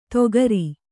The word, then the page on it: ದೋಕರಿ: dōkari